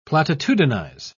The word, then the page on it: platitudinize: British English